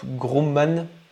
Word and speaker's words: Grumman: Il y a Northrop, Group, oh, j'arrive pas à le prononcer, euh, euh, Northrop Grumman, voilà, qui est une société d'armement aux États-Unis.